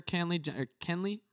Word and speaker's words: Kenley: So the Dodgers closer, Kenley, is that right? – Yes.